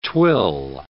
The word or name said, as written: twill